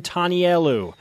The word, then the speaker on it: Tanielu: His name is Handsome Tanielu.